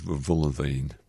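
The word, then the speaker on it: Wohlleben: It's Wohllenben. I pronounce it as my German-speaking wife told me it was Wohllenben.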